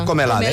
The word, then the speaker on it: Comelade: I acabem desitjant un bon dia al músic Pascal Comelade. Com es pronuncia? – Comelade. – Comelade, eh? – Sí.